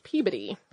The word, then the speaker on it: Peabody: I could be mistaken since it is the museum name, but the town of Peabody is pronounced Peabody.